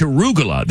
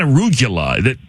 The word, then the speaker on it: arugula: But man, it's arugula. It's not arugula. He is adding syllables.